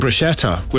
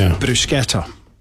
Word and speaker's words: bruschetta: CH in Italian is pronounced K. So when we're saying bruschetta, we're getting it wrong every time. It's bruschetta.